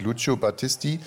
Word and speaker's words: Lucio Battisti: Es gibt es Lucio Battisti, Il mio canto libero. – Warte mal. Also ich finde, das ist relativ gut ausgesprochen. Also der heißt wirklich Lucio Battisti.